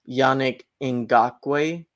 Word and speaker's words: Yannick Ngakoue: So the way you pronounce it, Zac, is Yannick Ngakoue is the pronunciation.